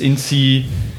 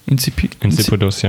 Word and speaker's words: insipidus: Und da kam dann Diabetes insipidus. – Insipidus, ja. – Insipidus. Ja, die Betonung ist wichtig.